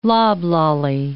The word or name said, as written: loblolly